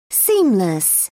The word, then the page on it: seamless: British English